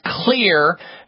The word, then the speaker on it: Xlear: clear nasal spray with xylitol. Clear is spelled with an X. X is for xylitol. X-L-E-A-R.